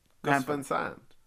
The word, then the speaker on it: Gus Van Sant: Gus Van Sant wrote in and said, how would you pronounce Gus Van Sant? – Gus Van Sant. I feel like it's in Americanized.